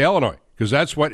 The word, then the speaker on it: Illinois: And the state of Illinois, not only is, first off, you can't pronounce the S at the end, right? We all know that you can't say Illinois, right? Everybody understands the S is silent.